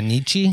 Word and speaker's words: Nietzsche: Oh, yeah, Nietzsche.